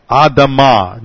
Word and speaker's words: Adamah: It is the Hebrew word Adamah. You just spell it like Adam, except you put an AH on the end of it, and this is setting up a pun.